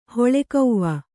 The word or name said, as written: ಹೊಳೆಕೌವ